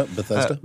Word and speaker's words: Bethesda: They love to see photos of him shopping in that, what's that place, Bethesda? Is that how you pronounce it? – Yeah, Bethesda.